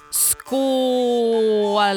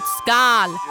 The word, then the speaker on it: skål: It's pronounced skål and not skål, as many pagans say. Look, I know it's hard for a non-Swede to pronounce it correctly, so I'm not bashing anyone. But it just sounds so funny when people say skål, because in Swedish, that means fruit peel.